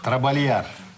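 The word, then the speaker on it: trabalhar: Трабальяр. И заодно отметим, что вот это сочетание читается как «ль».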